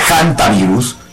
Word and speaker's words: hantavirus: Se pronuncia hantavirus.